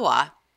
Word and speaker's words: Waugh: And that's his last name is spelled W-A-U-G-H. Wah? – Yeah, I think it's Wah. – Bill Wah.